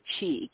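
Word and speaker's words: qi: They call it, I think they pronounce it chi, Q-I.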